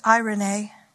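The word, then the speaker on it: eirene (εἰρήνη): So the peace in these two verses that's being spoken about is a Greek word, and it's pronounced eirene.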